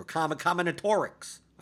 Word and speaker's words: combinatorics: But that combination, like, that's where we get to combinatronics or combinatorics. I always say that wrong.